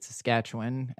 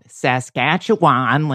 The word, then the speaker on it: Saskatchewan: Also, justice for having the character who's supposed to be from Saskatchewan pronounce it Saskatchewan and not Saskatchewan, like she's an American like me.